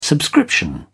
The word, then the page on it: subscription: British English